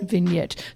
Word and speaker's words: Vignette: But it's a G in there, but it is pronounced Vignette.